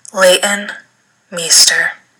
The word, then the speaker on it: Leighton Meester: Leighton Meester.